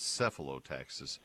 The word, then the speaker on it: Cephalotaxus: It's in quotation marks, Y-E-W-T-O-P-I-A. And let me get back to his report. It's Cephalotaxis is the genus. It's not a true U. It's not Taxus. It's Cephalotaxis. – S-E-P-H-O. – No, it starts with C-E. It's C-E-P-H. – Yeah, C-E-P-H-A. L-O-T-A-X-U-S, Cephalotaxis.